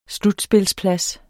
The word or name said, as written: slutspilsplads